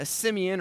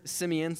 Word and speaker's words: Simeon: But there's something that you will continually also see with these, the first two words, Simeon Peter, is you see a Simeon or Simon. You can pronounce it both ways, but here it's Simeon, so we'll say Simeon.